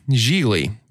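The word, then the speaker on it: Gigli: A lowly thug, Geely, rhymes with really, is assigned to kidnap the psychologically challenged younger brother of a powerful federal prosecutor to save his mobster boss from incarceration.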